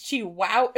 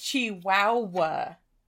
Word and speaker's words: Chihuahua: And I do love how it has how you pronounce Chihuahua in Chihuahua. – Parentheses on the cover. – Because it's Chihuahua. It's Chihuahua. – Yeah. – Chihuahua. Exactly. – Yeah, that's how you say it, right? – It's Chihuahua.